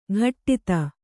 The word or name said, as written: ಘಟ್ಟಿತ